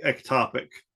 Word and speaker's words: ectopic: They're like, well, what about ep- epitopical? Right? Is that the right way to say it? I never. – e- ectopic. – Yeah. Ectopic pregnancy.